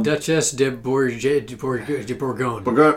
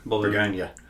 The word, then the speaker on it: Duchesse de Bourgogne: Duchesse de Bourgogne. Bourgogne. – Yeah. Like Bologna. – Bourgogne.